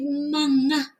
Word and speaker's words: manga: Be careful with your pronunciation with manga. Manga. Manga. Because, you know, the fruit mango.